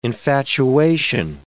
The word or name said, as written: infatuation